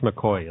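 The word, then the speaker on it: McCaughey: So it actually checked on this, and I'm convinced they were right that this Betsy, I can't remember, McCoughlin, was that? – It's called, it's pronounced McCoy. It looks like McKay.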